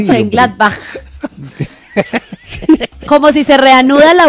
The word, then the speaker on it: Mönchengladbach: Borussia Mönchengladbach, pronuncias ahí el batch final como una J, Borussia Mönchengladbach.